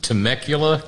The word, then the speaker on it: Temecula: Is it Temecula, California? – That's correct.